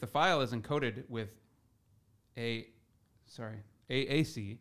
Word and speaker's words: AAC: However, the thing about it is that with that codec, if you have a file that's encoded ACC, right? – AAC. Yep. – Sorry, I keep saying that incorrectly.